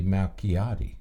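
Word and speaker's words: Malchiodi: So this would be Malchiati.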